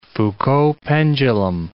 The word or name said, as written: Foucault pendulum